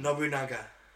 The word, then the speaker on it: Nobunaga: Nobunaga.